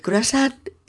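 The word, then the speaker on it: cruasán: El croissant es el universal croissant francés, la media luna de hojaldre, que adaptado a la fonética y a la grafía españolas se escribe croissant C r u a s a n Croissant. Y se acentúa en la última A, como palabra aguda que es, acabada en N.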